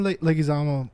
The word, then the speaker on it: Leguizamo: And, like, what actors, when John Leguizamo, did I say it right? – Mm-hmm.